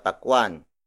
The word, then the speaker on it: pakwan: For example, apat na pakwan.